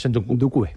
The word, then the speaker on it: Ndoukouré: Por cierto, en el canal de YouTube del enganche, ahí te lo pasé, hay un análisis, brutal de, creo que se pronuncia en Ndoukouré, ¿no? – Es Ndoukouré.